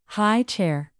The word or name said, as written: high chair